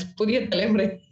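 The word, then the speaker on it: Puthiya Thalaimurai: She's been part of the core launch team for ET Now and Puthiya Thalimurai. Did I pronounce that correctly? Just correct me, please. – It's Puthiya Thalimurai.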